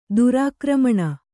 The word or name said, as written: ದುರಾಕ್ರಮಣ